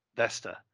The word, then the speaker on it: Vesta: And are we doing a Vista? Vesta. It's Vesta, not Vista. We said it wrong all these years.